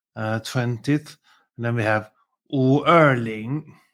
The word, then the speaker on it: 五二零: And it started because 五二零, which is how you say 520 or the 20th of the 5th in China. In Chinese, so that sounds like 我爱你.